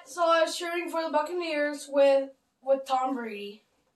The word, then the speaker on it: Buccaneers: The Tampa-neer Buccabays. – No, the Tampa... The Tampa... – Tampa-neer Buccabays. – No, Tampa-neer Buccaneers, I think.